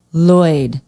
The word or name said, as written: loyd